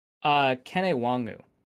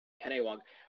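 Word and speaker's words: Kene Nwangwu: But I believe it is Kune Ungangwe. – Kene Wangu. – Kene Wangu. For some reason, the thing that I read said that the first E in his first name was an U, so they didn't know either.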